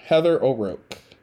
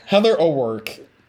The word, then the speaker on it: Heather O'Rourke: So let's just quickly discuss the main character, Heather O'Rourke. I keep saying her name wrong. Oh my God, it's so sad. Heather O'Rourke. There we go.